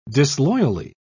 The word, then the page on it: disloyally: British English